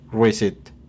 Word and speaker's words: receipt: La pronunciación fonética es R-I-C-I-T. – Receipt.